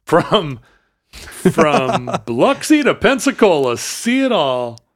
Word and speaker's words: Biloxi: Biloxi? – Biloxi, I think is how they say it.